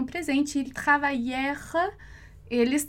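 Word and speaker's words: travaillèrent: Do mesmo modo, assim como no presente, a gente não vai pronunciar esse ENT ali no final da conjugação. Então, il travaillère, tá? Ali no final, com esse E mudo, justamente, ok?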